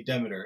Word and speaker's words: Demeter: Uh, so in Europe, they say Demeter a lot, but some people say Demeter. Here in the U.S., we say Demeter, and I don't know why, but that's that's the pronunciation we use.